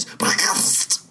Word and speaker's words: Brecht: Anyway, we were working on Arturo Uy by Brecht. I think that's the correct pronunciation. Some people say Brecht, but of course the correct pronunciation is Brecht.